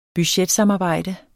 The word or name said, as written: budgetsamarbejde